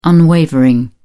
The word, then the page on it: unwavering: British English